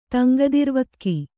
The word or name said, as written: ತಂಗದಿರ್ವಕ್ಕಿ